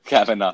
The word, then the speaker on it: Kavanaugh: The Kavanaugh.